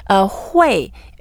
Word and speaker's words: 会: Hui is the fourth tone. So when we pronounce it, we go down first. Hui.